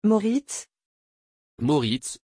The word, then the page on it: Moritz: French